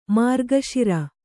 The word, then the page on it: ಮಾರ್ಗಶಿರ: mārga śira; Kannada